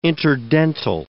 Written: interdental

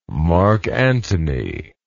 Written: marc antony

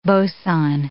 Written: boson

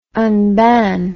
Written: unban

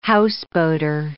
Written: houseboater

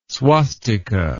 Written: swastika

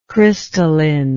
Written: crystallinity

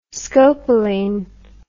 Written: scopoline

